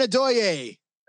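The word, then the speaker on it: Adeoye: How do I pronounce this last name? – Aaron Adoye. – Adoye, is that? I wasn't sure. It was like Christian Okoye in a way.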